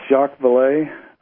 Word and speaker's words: Jacques Vallée: Is it Jacques Vallée? Is that how you say his name? – Yes, Jacques Vallée, a well-known French-born scientist who was an astronomer, mathematician.